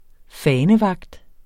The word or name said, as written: fanevagt